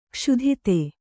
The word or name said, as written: ಕ್ಷುಧಿತೆ